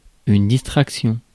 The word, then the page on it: distraction: France; [dis.tʁak.sjɔ̃]